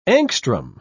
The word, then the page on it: angstrom: British English